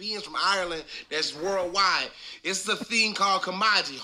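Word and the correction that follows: Camogie: Kamaji, Kamaji, Kamoji.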